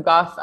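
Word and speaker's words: Gough: So I'm over at Emily Goff Coach on Instagram. So Goff, I'm sure it's spelled here somewhere, but it's G-O-U-G-H.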